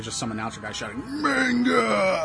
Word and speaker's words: manga: But it technically should be pronounced manga.